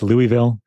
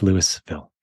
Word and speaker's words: Louisville: If you were in Kentucky, it would be pronounced Louisville. But here in Colorado, we say it like Americans, and it's Louisville.